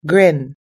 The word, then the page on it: grin: American English